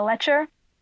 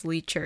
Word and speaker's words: lecher: Isn't it pronounced... No, it's not pronounced leecher, isn't it? – No! That's something else!